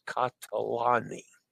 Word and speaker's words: Catalani: Catalani reminder that the Italian syllables generally follow a consonant, vowel, consonant, vowel, consonant, vowel format.